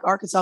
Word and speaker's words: Arkansas: Some people don't know that's how you pronounce it. And you would think that, you know, you learned your state, but no. Or people from foreign countries think it's Arkansas. And that's okay. It's an Indian word. And the S on the end is silent or has a W sound.